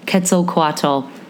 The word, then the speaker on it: Quetzalcoatl: This aged couple instructed Quetzalcoatl, that is how you say it, to create the world.